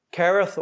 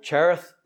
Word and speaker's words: Cherith: Whenever God sent Elijah to the brook Cherith, or brook Cherith, depends how you want to pronounce that word.